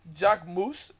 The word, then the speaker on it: Jacquemus: Jacquemus is the way you pronounce that, by the way.